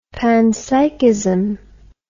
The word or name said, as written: panpsychism